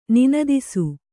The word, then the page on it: ನಿನದಿಸು: ninadisu